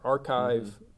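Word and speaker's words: arXiv: In the old days, It's spelled archive is spelled with an X in the middle because that's Chi.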